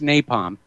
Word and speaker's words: napalm: Okay, it's pronounced napalm.